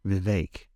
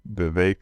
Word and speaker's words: Vivek: Mr. Vivek? – Vivek. – Okay. – Yeah. Because he said it, in his interview, he said Vivek like cake.